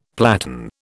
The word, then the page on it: platen: British English